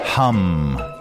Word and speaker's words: Hum: Hum, Croatia. I believe that's how you pronounce it. It's H-U-M, so Hum, Croatia, is the smallest town in the world.